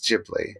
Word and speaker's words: Ghibli: But Ghibli, it's Ghibli.